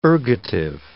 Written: ergative

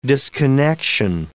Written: disconnection

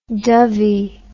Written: Dovey

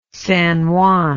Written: San Juan

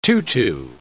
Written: tutu

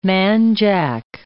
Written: man jack

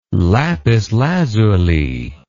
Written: lapis lazuli

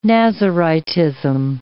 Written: Naziritism

